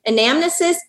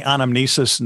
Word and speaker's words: anamnesis: Because I've heard it pronounced anamnesis and anamnesis. – Yeah, both. I've heard it said both ways. I usually say anamnesis, and I don't know why.